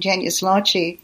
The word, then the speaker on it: genius loci: But the term genius loci, or as I pronounce it with the Italian, genius loci,